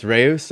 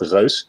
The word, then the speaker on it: Reus: Reyes is the easy pronunciation, actually. So in Dutch, we call it Reus. Reus is actually the Dutch word for giant, as many people have pointed out everywhere online. But that's the hard pronunciation, so we just call it Reyes whenever we talk in English.